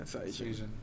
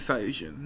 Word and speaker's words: Saiyajin: Jin is human, man. Saiya is space. – So spaceman. – Spaceman. Saiyajin. The Japanese pronunciation of Saiyan is Saiyajin, in case anyone wanted to know.